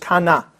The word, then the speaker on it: Cana: Which is actually Cana. But because we pronounce the we don't change it to the strong A, it's just kana.